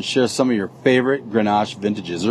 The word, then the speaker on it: Grenache: And it's pronounced Grenache in the States and Garnacha in Spain.